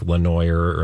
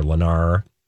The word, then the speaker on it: Lenoir: It's probably pronounced like Lenoir or Lenar. Lenar City in Tennessee.